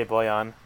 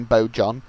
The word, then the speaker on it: Bojan: it took us 3 years to learn how to say Boyan, to be fair, and that's like two syllables. – And they still don't. They're still calling Bojan. – I still call him Bojan out of principle.